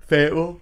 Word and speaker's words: Fayetteville: How do you say it? – Fayetteville. Or Fayette, you could say Fayetteville.